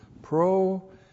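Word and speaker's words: pronoeo (προνοέω): Looks like that in the Greek, P-R-O-N-O-E, long O, pronoeo.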